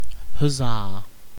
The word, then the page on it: huzzah: British English